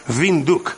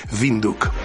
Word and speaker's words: Windhoek: Por cierto, aprovecho ahora para decirte que el nombre de la capital de Namibia se pronuncia Windhoek. No es Windhoek, ni nada por el estilo, es Windhoek.